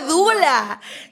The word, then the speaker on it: doula: Una dula, dula, dula. Y una dula que se escribe D-O-U-L-A.